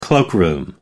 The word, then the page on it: cloakroom: American English